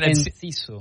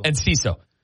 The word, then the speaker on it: Enciso: Enciso. – Enciso.